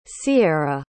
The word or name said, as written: Syeira